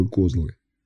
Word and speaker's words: козлы: «Вы знаете, что такое козлы?» «Именно так, с ударением на «о». Это не эти парнокопытные с бородой и рогами, и даже совсем не те мужики, что любят разнообразие в любви, по словам женщин.